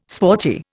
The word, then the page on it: sporty: Indian